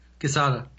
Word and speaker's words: Quesada: Is that how you say his name? – Quesada. – Joe Quesada.